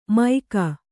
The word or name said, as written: ಮೈಕ